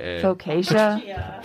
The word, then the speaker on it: focaccia: It's F-O-C-A-C-C-I-A. – Focaccia? – Focaccia, yeah.